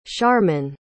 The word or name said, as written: sharman